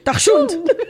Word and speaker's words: dachshund: D-A-C-H-S. – D-A-C-H. Okay, Dach. – S-H-U-N-D. – Dachshund. Oh, my God. Okay, so dachshund.